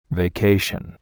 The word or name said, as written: vacation